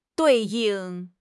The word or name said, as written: 对应